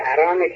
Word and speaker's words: اران: یه تیکش هم درست تلفظ ارانه که همین تلفظ دیگه ایست از ایران.